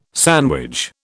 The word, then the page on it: sandwich: British English